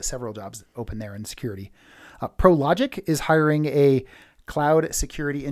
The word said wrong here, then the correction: Prologis: That's Prologic, not Prologis? – It's Prologis.